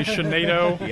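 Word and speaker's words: Tsienneto: I've been on other radio shows and we've had people call in and say, how do you pronounce that word that starts with TS? – I've heard people say Shenito. I've heard them say Shenado. – Yeah. – I'm not sure what the official designation is.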